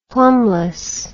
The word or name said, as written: plumbless